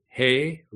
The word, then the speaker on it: He: The next letter is H, the He, Yod, then He is H-E, it's pronounced He.